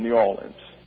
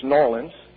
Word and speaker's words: New Orleans: Now, that's the correct pronunciation, New Orleans.